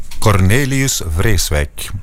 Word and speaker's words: Cornelius Vreeswijk: En dat is, kunnen wij misschien eens vragen hoe men in Zweden zijn naam zou uitspreken? – Cornelius Vreeswijk. De Zweden zijn ook wel correct met het uitspreken van Nederlandse namen.